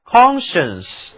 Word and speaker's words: conscience: 如果在科学前面添上 CON，这是一个表示加强意义的前缀，构成的单词是 conscience SC 字母组合发音是 sh， conscience 它的意思是良心、道德心。